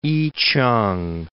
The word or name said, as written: I-ch'ang